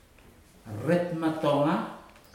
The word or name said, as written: retu matoga